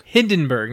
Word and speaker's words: Hindenburg: Hindenburg, not Heidenberg.